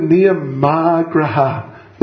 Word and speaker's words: Niyamagraha: We don't want to get too much into Sanskrit grammar, but this word Niyamagraha, there's a long A. Are you with me? Niyamagraha. Niyamagraha. An A with a line over the top, as you know, it appears in our books.